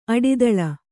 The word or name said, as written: ಅಡಿದಳ